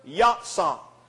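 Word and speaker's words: yatsah (יָצָא): That Hebrew word, go forth, is the Hebrew word, yatsah. It is spelled Y-A-W, T-S-A-W. And another spelling for it is Y-A-T-S-A.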